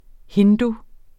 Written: hindu

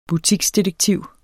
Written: BUTIKSDETEKTIV

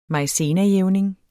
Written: maizenajævning

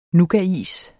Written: nougatis

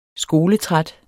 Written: skoletræt

